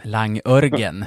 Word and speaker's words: Langörgen: Klassiken är väl Eskilstuna Uniteds Langjörgen. Nej? – Langörgen. – Langörgen, det korrekta.